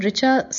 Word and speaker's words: Richa: Hello, my name is Richa Jha, R-I-C-H-A-J-H-A. Now the word Richa sounds very simple where I come from. I come from India. There the words C-H together pronounced as Ch, Ch as in chair and not chemistry, not K.